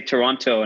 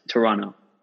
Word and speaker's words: Toronto: People say Toronto, and I'm like, no, it's Toronto.